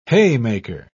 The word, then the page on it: haymaker: British English